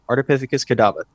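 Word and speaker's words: Ardipithecus kadabba: Ardipithecus cadaba. – Very good, very good. So Ardipithecus cadaba, that's correct.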